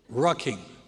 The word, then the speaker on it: rucking: That's with an R. Rucking. R-U-C-K-I-N-G. I had to spell it, as you thought it was another word that I was saying.